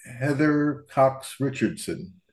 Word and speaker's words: Heather Cox Richardson: But anyway, you posted something that you quoted Heather Cox Richardson.